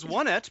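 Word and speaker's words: Onett: It is Onette.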